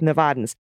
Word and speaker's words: Nevada: as it seems to be a sensitive topic with Nevadans. There was a commercial done on how to pronounce it. Nevada, where VOD rhymes with bad, is the correct way.